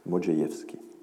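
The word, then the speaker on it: Modrzejewski: Et donc, voilà, le RZ se prononce J. Modjeïevski. Et Modjeïevski décrit, fait au passage, d'ailleurs c'est intéressant, l'ensemble des textes antiques qui parlent des Juifs.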